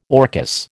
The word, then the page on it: orchis: American English